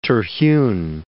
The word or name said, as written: Terhune